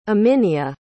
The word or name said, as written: aminia